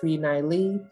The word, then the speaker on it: NiiLee: A pun on Miley Cyrus, so I did NiiLee Cyrus just so people can pronounce it correctly.